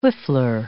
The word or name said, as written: whiffler